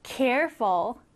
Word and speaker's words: careful: 두 번째 단어는 careful. Careful. 자, 여러분. Careful은 이렇게 앞에 강세가 들어갑니다. 앞에 들어가서 careful 이렇게 발음을 해요.